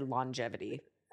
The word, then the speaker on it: longevity: And I just said longevity. – No, you said it right. – Okay. – There was a nice G. – Soft, but soft. – It was there.